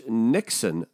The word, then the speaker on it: niksen: But uh, this Dutch Niksen thing, and I believe that's how you pronounce it.